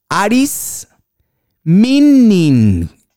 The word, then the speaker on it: Aris Mining: Quédense con este nombre, lo voy a leer como se escribe. Aris Mining, M-I-N-I-N-G, Aris Mining, que ya opera en Colombia con proyectos de oro en Marmato, en Segovia y en Santurbán.